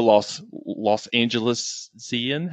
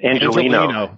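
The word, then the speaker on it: Angeleno: Well, thank you so much again for talking with us late on a Thursday night as a fellow Los Angelesian. How do you say it? – Angelino. – There we go.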